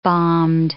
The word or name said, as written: bombed